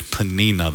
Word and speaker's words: Peninnah: The name of one was Hannah, and the name of the other was Peninnah. Man, all these names. Actually, I think in more Hebrew way, it would actually be pronounced Peninnah. So let's use Peninnah this morning.